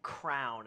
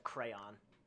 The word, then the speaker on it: crayon: like saying That's like saying crown instead of crayon.